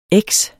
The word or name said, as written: eks